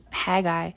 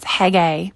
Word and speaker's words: Haggai: And the reason I'm zeroing in on Haggai, I'm actually mispronouncing his name, it's Haggai.